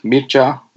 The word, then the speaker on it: Mircha: My name is pronounced Mircha, like Mir and Cha, like in, you know, the dance, Cha-Cha.